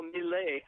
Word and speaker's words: Milley: And he was reacting to Tucker Carlson's widely quoted remark that General Milley, I think I'm pronouncing that right, am I, Frank? – I believe you are.